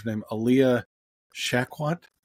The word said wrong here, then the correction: Shawkat: Shawkat, yeah, Shawkat. – It's Alia Shawkat.